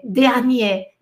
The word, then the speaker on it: dernier: Porque é ER. E quando o e, o e vem com R numa, voga, numa sílaba, ele tem E, é, dernier, dernier dernier, desculpa, dernier, dernier. E, e, ali, no meio da palavra, ele tem som de E por causa do R.